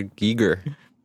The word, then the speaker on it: Giger: I think we're gonna say Jijir. People are gonna think we're actually saying Jijir. I forgot his real pronunciation. What, H.R. Giger? – Yes.